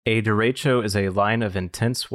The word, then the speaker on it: derecho: Also, real quick, I want to correct my pronunciation. It's derecho. – Is that like derecho.